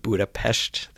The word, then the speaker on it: Budapest: And by the way, great pronunciation of Budapest there.